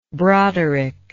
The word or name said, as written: broderick